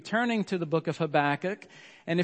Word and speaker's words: Habakkuk: And so I'd like for you to pronounce that out loud with me with emphasis on the first and last letter, okay? Habakkuk.